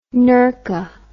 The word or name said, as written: nerka